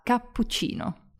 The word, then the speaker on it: cappuccino: Then, of course, you can ask for a cappuccino, too. Listen carefully, making the P and the CH really nice and long.